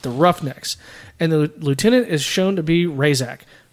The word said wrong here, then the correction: Rasczak: from their high school. – Radcheck. – I'm going to go with you on the pronunciation. – It's Radcheck. He's right. – Radcheck. Spelled R-A-S-C-Z-A-K.